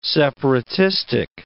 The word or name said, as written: separatistic